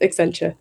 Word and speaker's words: Accenture: No worries, it's Accenture. You're very close.